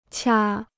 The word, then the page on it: 掐: qiā